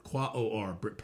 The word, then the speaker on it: Quaoar: Quaoar probably is the better pronunciation. Q-U-A-O-A-R, another planet out near where Borsisi is, which has a lot to do with what you believe.